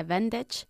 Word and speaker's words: bandage: The A-G-E ending of bandage is pronounced exactly the same as the A-G-E ending of cabbage. – Okay. – A cabbage and bandage. It's casi como una I latina CH.